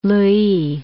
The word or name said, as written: Louys